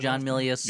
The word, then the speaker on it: John Milius: The John Milius, is that how you say it? – John Milius.